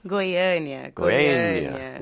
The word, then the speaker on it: Goiânia: Is it Goiânia? – Goiânia.